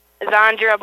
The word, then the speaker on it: Zahndra: Zandra Bordewick. – Oh, Zandra. That's a pretty name. How do you spell that? – Z-A-H-N-D-R-A.